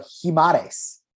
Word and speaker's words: Guimarães: So I think it's Bruno Guimarães.